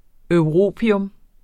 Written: europium

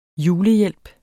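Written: julehjælp

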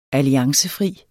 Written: ALLIANCEFRI

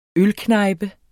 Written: ølknejpe

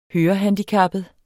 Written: hørehandicappet

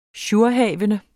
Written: jourhavende

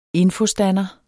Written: infostander

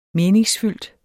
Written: MENINGSFYLDT

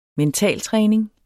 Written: mentaltræning

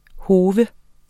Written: HOFS

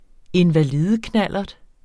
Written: invalideknallert

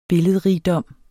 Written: billedrigdom

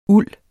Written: ulden

